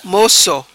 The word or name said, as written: Moco